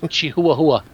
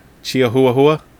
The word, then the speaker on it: Chihuahua: It's pronounced Chihuahua. – Chihuahua? Yeah, that's, yeah, that's okay. how it's pronounced.